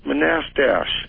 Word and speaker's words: Manastash: Do you know where Manistash? – Yeah, it's pronounced Manastash.